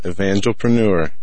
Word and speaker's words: Evangelpreneur: Evangelpreneur. – Evangelpreneur. See, I want to put the emphasis on the different syllable.